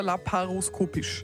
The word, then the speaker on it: laparoskopisch: Heißt das laparoskopisch oder laparoskopisch, so wie es die Sandra, glaube ich, ausgesprochen hat? – Es geschrieben wird Laparoskopie oder laparoskopisch. – Ja. Das heißt, du sagst laparoskopisch.